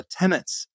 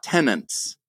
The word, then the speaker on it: tenet: tenets of the Baha'i faith. And Reza, it's tenet, not tenants.